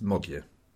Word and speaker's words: Magyar: Just while I'm on it, you may well have seen the word Modiar written down as Magyar, M-A-G-Y-A-R. Just believe me, it's pronounced Modiar.